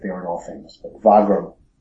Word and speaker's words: Wagram: Or Wagram, as Americans pronounce it. I believe it's pronounced Wagram.